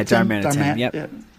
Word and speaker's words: Darmanitan: Like, you think about Galarian am I saying that right, though? Darmanitan? – Yeah, Darmanitan.